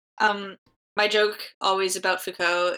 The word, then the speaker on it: Foucault: And Shaking the Habitual takes its title from a quote by French philosopher Michel Foucault. Is that how you pronounce it? – Foucault.